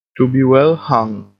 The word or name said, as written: to be well hung